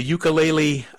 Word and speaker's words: ukulele: You pronounce it differently, though, so I'd like to hear how you pronounce it. And I know it's not indigenous to Hawaii, but it's become a huge part of Native Hawaiian culture. Give us a short history of how that happened. – Sure. It's pronounced as ukulele,